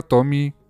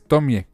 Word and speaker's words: Tomie: Yo pensaba que se pronunciaba Tommy. No, es Tomie. Así, el señor Junji Ito hace poquito que hizo su...